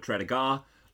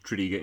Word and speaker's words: Tredegar: Don't be tempted to call it Tredegar. Lots of out-of-towners do. It's Tredegar. Tredegar. There you go.